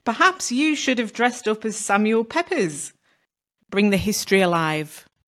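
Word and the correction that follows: Pepys: Isn't it pronounced Samuel Peeps? – Is it? Is that me just not pronounce? You know when you've never heard a word said out loud? I'm doing that right now. – Yeah, I think it's Samuel Peeps.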